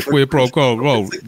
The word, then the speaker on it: quid pro quo: To me, that's like, it's more like, yo, it's like pre-quo. What is it? Is that how you say it? How do you say that? You know what I'm talking about. – Quid pro quo. – Yeah, quid pro quo.